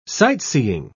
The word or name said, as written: sightseeing